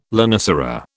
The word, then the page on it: lonicera: British English